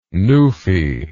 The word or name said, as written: newfie